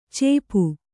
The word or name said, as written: ಚೇಪು